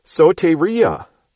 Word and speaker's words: soteria: For if Paul had meant salvation, he would have used the word soteria, S-O-T-E-R-I-A. Again, with a long O and a long E, representing omega and eta.